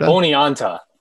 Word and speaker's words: Oneonta: Oneonta? – Oneonta.